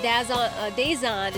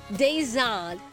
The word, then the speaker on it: Dazhon: Marty, the text line said, don't forget Dazon is how you say it. D-A-Z-H-O-N, by the way. Dazon.